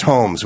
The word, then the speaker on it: Homs: It's pronounced Homs, which is hilarious, but it's H-O-M-S, Homs, in Syria, because it's a stronghold of sedition and rebellion there.